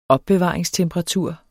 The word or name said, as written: opbevaringstemperatur